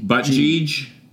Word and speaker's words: Buttigieg: Number four is Pete Buttigieg. – That's Iowa mayor guy. – Probably pronounced wrong. It's B-U-T-T-I-G-I-E. – Buttigieg.